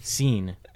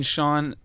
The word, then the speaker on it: Sean: S-E-A-N. This you'd think it'd be pronounced seen, but it's pronounced Sean.